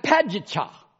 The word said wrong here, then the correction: Paducah: Did I say that right? No. How do you say that? P-A-D-U-C-A-H. Who? Paducah.